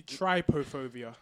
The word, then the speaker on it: trypophobia: I said it wrong the first time. It's actually trypophobia. Trypophobia.